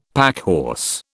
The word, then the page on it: packhorse: British English